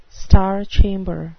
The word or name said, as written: star chamber